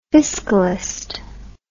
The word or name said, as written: fiscalist